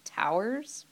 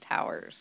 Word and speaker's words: tours: And they run 12-hour shifts, and they're called towers. They're spelled tours, pronounced towers. So that's a greenhorn thing that'll get you in trouble if you say you're on the night tour. It's pronounced tower.